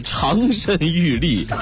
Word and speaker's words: 长身玉立: 他们看起来性别模糊，却妆容精致，长身玉立，但是却如弱柳扶风。啊，我是不是发音错了呀？我都不知道这怎么念了，应该是长身玉立。